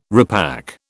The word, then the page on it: repack: British English